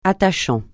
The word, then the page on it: attachant: francais